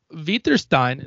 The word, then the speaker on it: Witterstein: My Witterstein is how you say my last name in German.